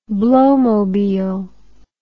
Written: blowmobile

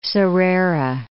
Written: Sarera Bay

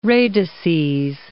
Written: radices

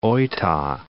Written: Oita

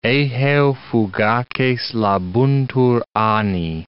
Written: eheu fugaces labuntur anni

